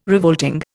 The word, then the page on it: revolting: Indian